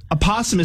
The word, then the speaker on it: opossum: Opossum is spelled O-P-O-S-S-U-M. Opossum. – Okay. – And it's not a possum.